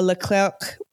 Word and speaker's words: Leclerc: And also a Leclerc main battle tank. – Boo, French pronunciation. – Leclerc. French.